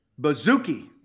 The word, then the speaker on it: Baszucki: And we know how to pronounce this. We know how to pronounce this, Doc, because it's spelled funny. It's B-A-S-Z-U-C-K-I. But we know how to pronounce it because we have a very easy way to remember. Just think of bubblegum.